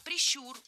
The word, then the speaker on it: прищур: Не прищур, а прищур. Ударение в этом слове ставим на второй слог. Да там его и оставляем во всех формах.